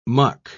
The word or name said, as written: muck